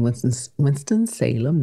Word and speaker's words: Winston-Salem: But I left New York when I was 10 months, so I've been told, and I went to live in Winston-Salem, North Carolina.